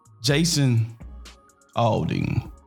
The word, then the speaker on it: Aldean: Jason Aldean. Yeah, that's how you say his name.